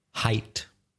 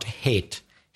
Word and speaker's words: height: N-E-N-G-T-H. What about wide? – Width. – With a D, so W-I-D-T-H. High? – Height. – H-E-I-G-H-T. – Careful with the pronunciation, not hate. Hate is audio. Height is altura.